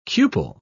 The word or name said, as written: cupel